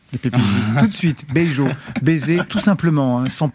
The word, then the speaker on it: Beijo: On va écouter tout de suite Beijo.